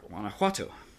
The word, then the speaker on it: Guanajuato: Guanajuato. – Yeah, Guanajuato. I want to say it right. – No, it sounds great.